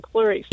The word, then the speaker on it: Clarice: So they spell it Cla-Reese. So it's, well, you know what? That's phonetically correct. – Claw Reese. – But if you know Science of the Land, I'm Clarice.